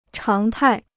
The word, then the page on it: 常态: cháng tài